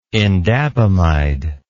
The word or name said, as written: indapamide